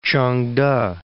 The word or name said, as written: Ch'ang-te